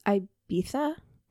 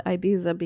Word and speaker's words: Ibiza: And Is Ibiza? – Why do people keep saying that? It's on TV. It's what is that? What is that from? – I think that's just how they pronounce it. – Who? – The people who live in Ibiza. We call it Ibiza because there's a Z.